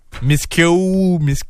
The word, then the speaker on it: Miskew: M-I-S-K-E-W. – Emma Miskiou. – Miskiou.